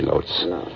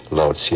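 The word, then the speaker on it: Łódź: In Lodz, actually, Lodz. Lodz, yes.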